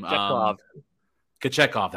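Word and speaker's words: Kochetkov: I know Carolina has the Kiochkev or whatever, however you pronounce his last name, Kachekov. That's right.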